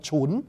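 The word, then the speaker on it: chún (唇): Còn có chữ thần thứ ba là cái môi của mình á, tiếng của mình đọc là thì là tiếng Hán đọc là chún, là CHUN.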